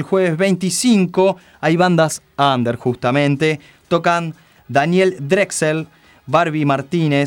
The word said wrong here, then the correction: Drexler: para, porque acabas de pronunciar mal el nombre de Daniel Drexler.